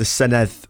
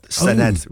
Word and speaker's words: Senedd: But unfortunately, a lot of people on the news can't seem to gist the fact that it's the Senedd with a th, not Senedd with a hard D.